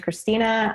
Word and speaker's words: Christina: So he already got the name right, but my name's Christina.